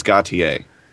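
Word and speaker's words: Gotye: I was about to say, is that really how you pronounce it? – According Gautier? To the YouTube video. – I was stumped. – Is Gautier. Maybe with more of an accent.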